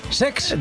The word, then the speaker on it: Cesc: El Como de Cesc Fàbregas, que se estrenó... – Lo he dicho mal, es Sex.